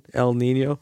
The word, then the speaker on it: El Niño: In Spanish, they pronounce it with a nya over the N, El Nino.